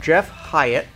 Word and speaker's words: Jeff Hyatt: Today, I have Jeff Hyatt.